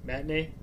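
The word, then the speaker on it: Mae Tinee: The review in question written under pseudonym May Tine, pronounced like Manatee. Oh, I get it. Matinee.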